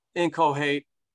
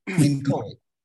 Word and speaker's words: inchoate: Inchoate, and it took me a while to pronounce this word right, so. – Inchoate. – Yes, I'll say it wrong about four times.